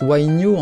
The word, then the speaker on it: huayno: qu'on prononce huayno en Bolivie, ou le carnavalito qui lui ressemble beaucoup.